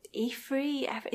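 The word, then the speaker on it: Eaffrey: it's spelt E A double F R E Y. Efree, it sounds kind of reminds me mm.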